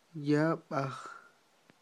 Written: yab'aj

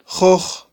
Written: joj

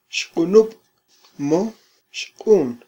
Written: xqunub mo

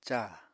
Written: càa

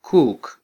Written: kuk

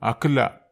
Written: akela

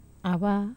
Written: ava

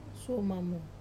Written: suo mamu

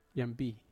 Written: ampi

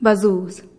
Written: bazuuz

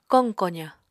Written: koq kozañ

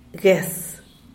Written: gues

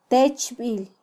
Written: techbil

